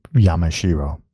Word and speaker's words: Yamashiro: And my pronunciation is straight on for the record for Yamashiro.